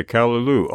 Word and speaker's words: callaloo: We're going to hear Stewart's own music, a piece called Callaloo. Have I said that correctly, Stewart? – That is correct, yes. – Okay, Callaloo.